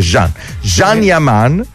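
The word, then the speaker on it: Can Yaman: perché ho scoperto che non si dice Khan ma si dice Jean Yaman, che l'attore è turco, quello.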